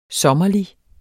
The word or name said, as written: sommerlig